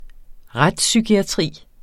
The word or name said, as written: retspsykiatri